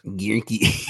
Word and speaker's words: Guericke: Gierke. – Gierke. – Gierke. – G-U-E-R-I-C-K-E.